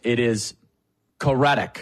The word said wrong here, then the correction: Currituck: Is that right? Oh, my goodness. No, hold on. No. It's Currituck.